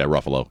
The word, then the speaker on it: Ruffalo: And Mark Ruffalo. Is that how you say it? – Yeah, Ruffalo.